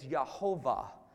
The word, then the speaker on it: Yehovah: The rabbis had to come up with a way to pronounce it. So what they did is they took the vowels from Adonai, which means Lord. They put it between the YH and the VH, and it says Yehovah.